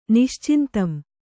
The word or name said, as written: ನಿಶ್ಚಿಂತಂ